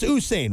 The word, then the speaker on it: Usain: Am I saying that right? It's Usain, right? Usain Bolt? – Yeah, Usain Bolt.